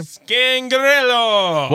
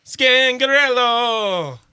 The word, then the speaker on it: Scangarello: And all I want to know is just from this past season for the Denver Broncos, how do you say his last name? – Scangarello. – One more time. – Scangarello. – Hot.